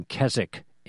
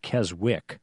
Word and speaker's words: Keswick: And there was also a revival in Keswick, England. It looks like Keswick. It's pronounced Keswick.